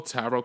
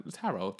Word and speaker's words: tarot: Like, bitch, this week, like, first of all, this whole tarot card, this whole tarot shit, like, T-A-O, I don't know how to pronounce that. Tarot, bitch, why are you pronouncing the T at the end? Like, tarot.